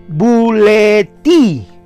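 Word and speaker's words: bouleti (βούλεται): Él quiere, la palabra querer procede del griego bouletei, volutai, se escribe bouletai, pero es como las, eh, cuando están unidas las vocales ou suena u y la ai suena i, sería bouleti.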